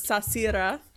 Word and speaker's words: Sarcyra: So I'll put it phonetically like how Amy does lead in our Zoom chat, but it's Sasira. It's so Sasira. It's doesn't look like how it's spelled, Sashira. – But I love that. – It's like sashimi Sashira. – Sasira. Don't Shira. Sasira.